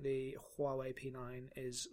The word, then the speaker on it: Huawei: My understanding from our Chinese colleague who worked with us last year was that you don't pronounce the H. – Nope. – It's Huawei.